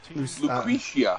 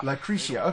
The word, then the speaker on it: Lucretia: And then we get into the next track, Lucretta, which you picked. So here's, and it's called Lucretia. – Lucretia. Okay, I said it wrong.